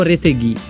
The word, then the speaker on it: Retegui: Retegui. Correggo che mi aveva già spiegato qual è la pronuncia corretta.